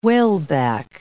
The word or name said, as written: whaleback